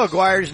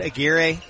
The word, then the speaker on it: Aguirre: Did you know Aguirre's name is supposed to be pronounced Aguirre? – Are you serious? – Yeah, he has Latin heritage.